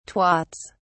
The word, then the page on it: twats: British English